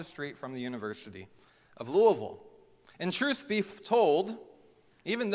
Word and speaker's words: Louisville: So for example, whenever I moved to Louisville, Kentucky, and it's pronounced Louisville, if you've ever been there, moved to Louisville, Kentucky for seminary, I became a University of Louisville fan, U of L.